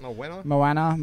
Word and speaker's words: Moana: Moana. Is that how you say it? – Moana.